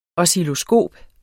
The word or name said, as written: oscilloskop